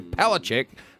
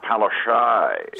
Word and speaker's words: Palaszczuk: Anastasia Palaszczuk, not happy with you at all. – I think it's pronounced Palaszczuk.